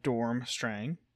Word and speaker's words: Durmstrang: So, the pronunciation of Durmstrang is Dormstrang.